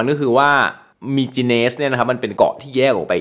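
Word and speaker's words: Mykines: มิจิเนส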